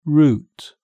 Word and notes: root: British English

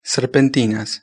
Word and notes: serpentinas: Spain Spanish

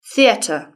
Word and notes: theatre: British English

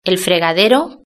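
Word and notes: el fregadero: Spain

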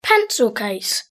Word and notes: pencil case: British English

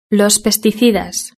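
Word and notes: los pesticidas: Spanish (Spain)

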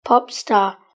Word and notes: pop star: British English

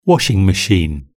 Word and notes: washing machine: British English

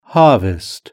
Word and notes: harvest: British English

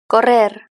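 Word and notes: correr: European Spanish

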